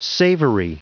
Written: savory

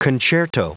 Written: concerto

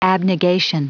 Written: abnegation

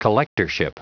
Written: collectorship

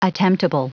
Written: attemptable

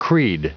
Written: creed

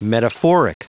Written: metaphoric

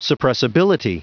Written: suppressibility

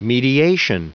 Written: mediation

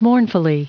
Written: mournfully

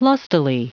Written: lustily

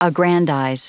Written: aggrandize

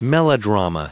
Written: melodrama

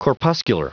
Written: corpuscular

